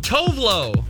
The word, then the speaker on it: Tove Lo: Tilo? – Who? – Tilo? Okay, T-O-V-E-L-O. – Oh, Tovlo.